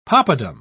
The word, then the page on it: poppadam: British English